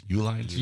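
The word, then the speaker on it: Uline: E-lines. How do you pronounce it? U-lines? – U-line. – Okay.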